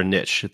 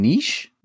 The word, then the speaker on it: niche: That's our niche, like demonstrating our community, showing people the various members, getting people to know them. – The fuck is niche? You mean niche? – Yeah, did I say it wrong?